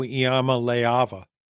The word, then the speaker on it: Iamaleava: Yes, and I've even practiced saying Nico Iamaleava. – That's as close as you've ever come, I think. – Yeah, and I notice you're not pronouncing that A. Lay, I think that's correct pronunciation.